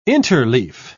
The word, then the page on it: interleaf: British English